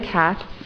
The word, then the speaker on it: Katz: My name's Julia Katz, and I live at 130A North Harvard, so I am directly behind the one side of the property.